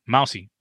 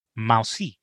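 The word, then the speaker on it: Mausi: That's right, Mousey, but not spelled like a mouse. It's M-A-U-S-I, Mousey.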